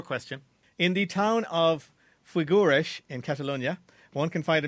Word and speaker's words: Figueres: Figueres. I spell it F-I-G-U-E-R-E-S.